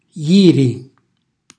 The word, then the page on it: jiri: Calabrese